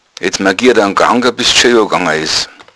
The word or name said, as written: magieren